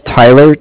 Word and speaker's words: Tyler: My name is Tyler, T-Y-L-E-R, and I'm calling with Cardiff. It's spelled C-A-R.